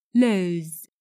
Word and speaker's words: lose: Lưu ý từ lose trong câu này viết là L O S E nhưng đọc là lose với âm cuối là Z Z lose.